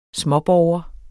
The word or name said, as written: småborger